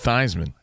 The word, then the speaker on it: Theismann: They wanted it to rhyme with Heisman, so they stuck with Theismann.